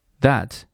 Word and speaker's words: that: That 结尾的 T sound。仍然是读一个半音，只做嘴型不发音。